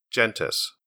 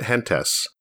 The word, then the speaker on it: Gentes: Gentes, or is it Gentes? I didn't take Latin in high school, but living in Southern California, I know a little Spanglish, so I pronounce it Gentes, which means people. And since Gentes is about civilization, that makes sense to me. But after reading a BGG thread about the proper pronunciation, it seems like more people prefer Gentes, so for this review, I'll go with that.